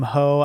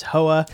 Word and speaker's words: Hoa: Yeah, like we were saying, you you called him Ho. I read it as Hoa. – it's spelled H-O-A. Yeah, H-O-A.